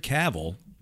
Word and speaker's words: Cavill: But one thing that's rumored to be announced is that Henry Cavill, rhymes with travel,